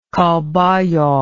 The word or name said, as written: Calbayog